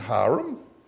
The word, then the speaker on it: harem: Isn't it harem?" No, look up your dictionary. It's harem. Yeah, that's how it's pronounced.